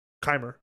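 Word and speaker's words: Kaymer: Brandon Grace, Dustin Johnson, Matt Jones, Martin Kramer, or Kamer. – Kimer.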